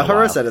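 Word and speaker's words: charoset: No, haro set is right.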